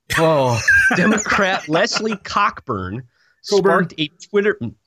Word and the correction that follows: Cockburn: It's Coburn. It's pronounced Coburn, but you can go ahead and say Cockburn. It's Coburn, but go ahead.